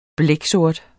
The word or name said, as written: blæksort